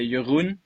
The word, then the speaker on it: Jeroen: I mean, it's a Dutch name, so you would say Jeroen, but I've heard all kinds of variations throughout the year.